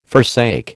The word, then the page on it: forsake: American English